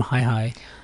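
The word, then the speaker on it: HiiH: So the way it's spelled is you have a capital H, a small I, another small I, and then a capital H. So if you look at it and you think it's a hi-hi, anyway, tell us it is pronounced hi-hi, and how did you come up with the name Hi-Hi?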